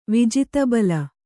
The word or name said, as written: ವಿಜಿತಬಲ